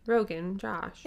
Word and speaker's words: Rogan Josh: Yeah, and I did look up how to pronounce it. I could still be saying it wrong, but the pronunciation was just Rogan Josh. – Yeah, what it looks like.